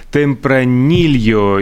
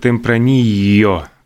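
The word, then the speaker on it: Tempranillo: Сегодня Международный день темпранильо, или, как в некоторых областях э, Э, Испания его называют Темпранио, потому что все-таки двойная «л» по-разному читается.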